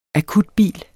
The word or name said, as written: akutbil